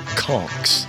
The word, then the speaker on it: conch: Native Keys folks are called conchs. That's spelled C-O-N-C-H and not pronounced concha.